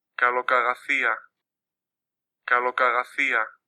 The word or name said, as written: Kalokagathia